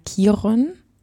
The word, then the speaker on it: Kiron: Ich ähm, bin zurzeit Curriculumskoordinatorin bei Kiron. So spricht man es nämlich aus.